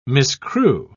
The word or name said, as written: miscue